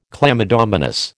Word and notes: chlamydomonas: American English

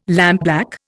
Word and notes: lampblack: Indian

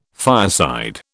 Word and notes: fireside: British English

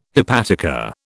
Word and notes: hepatica: British English

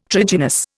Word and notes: trigynous: Indian

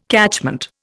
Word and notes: catchment: Indian

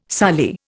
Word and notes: soli: Indian

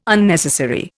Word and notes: Unnecessary: Indian

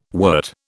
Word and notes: wort: British English

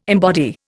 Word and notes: embody: Indian

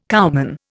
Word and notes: cowman: Indian

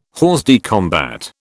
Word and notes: hors de combat: British English

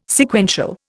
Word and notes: Sequential: Indian

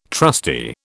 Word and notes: trustee: British English

